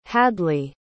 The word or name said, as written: hadlee